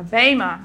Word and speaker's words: bema: It's a Greek word, bema, B-E-M-A, long E.